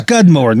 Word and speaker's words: Skudmore: Well, thanks for that information, Mr. Scummymore. – That's Skudmore. Typical of you Americans to have trouble pronouncing the English language. – Well, anyway, Mr. Scummymore. – Skudmore. – Skudmore, not Scummymore. Yes.